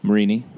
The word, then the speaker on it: Marigny: the Margny. I'm probably pronouncing that wrong. – Yeah, Marigny. Marigny. Oh, that makes more sense.